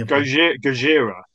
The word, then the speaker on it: Gojira: I'm sure I mispronounced that, but it's basically Godzilla in the original movie. – Gojira.